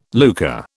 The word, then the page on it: lucre: British English